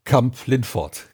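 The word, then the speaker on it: Kamp-Lintfort: Hallo Dennis aus Kamp-Lindfort.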